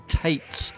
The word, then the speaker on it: Taetz: First of all, Alberta-born, Toronto-based artist Stephen Tates. And I hopefully I've said that right. T-A-E-T-Z, Tates.